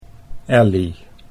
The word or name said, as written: Elly